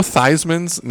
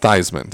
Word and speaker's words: Theismann: Joe Theismann's name is not pronounced Theismann. Did you know that? – What? – It's Theismann. And they changed it when he was in college so they could run a Heisman campaign for him. And to this day, everybody calls him Joe Theismann, and that is not how you pronounce his last name.